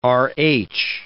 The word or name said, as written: Rh